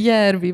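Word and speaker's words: Järvi: például a, a Szemi Járvi is elvileg valahogy. Járvi. Járvi, valahogy így kéne kiejteni helyesen.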